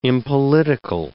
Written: impolitical

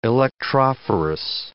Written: electrophorus

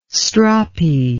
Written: stroppy

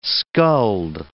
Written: skulled

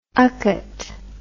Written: UCATT

